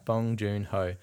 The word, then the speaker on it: Bong Joon-ho: What did I say? – You said it right. Bong Joon-ho.